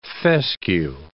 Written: fescue

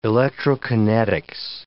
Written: electrokinetics

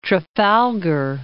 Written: Trafalgar, Cape

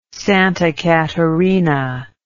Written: Santa Catarina

